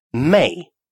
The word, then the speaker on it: mig: «Мэй» – «мне». «Мэй». Такое написание является просторечным. Грамматически верное состоит из букв «м», «и» и «г», а читается точно так же.